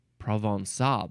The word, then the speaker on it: Provençal: Provençal pronunciation. It's got the little squiggle under the C. Provençal. Oh, yes. There we go. Provençal. That makes sense. Because it's French.